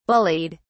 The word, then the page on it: bullied: British English